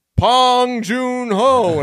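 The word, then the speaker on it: Bong Joon-ho: he just goes, Pong Joon-ho, and I was like, what the fuck was that? I looked it up. That is how you say his name.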